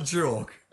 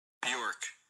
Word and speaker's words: Björk: Siri, it's definitely not Jork. All right. – Unless Jork is how you pronounce it. – No. There's a YouTube. It's taken to a YouTube link. – Yeah. Again. – Hang on, that was it. – Bjork. – Bjork. All right, you're right.